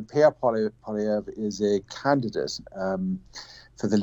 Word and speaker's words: Poilievre: Pierre Poilievre, which I can't pronounce properly.